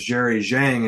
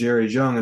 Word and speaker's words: Zhang: You're going to hear the name Jerry Zhang, which was Jerry Zhang until he started winning matches. And so we realized it was actually pronounced Jerry Zhang.